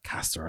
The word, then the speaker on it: Caster: Caster, I should say. That's how he pronounces it, whatever, you know.